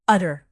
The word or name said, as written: utter